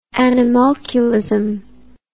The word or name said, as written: animalculist